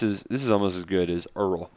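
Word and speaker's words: Erroll: Is Earl Thompson. – Two R's. – Rhymes with Merle.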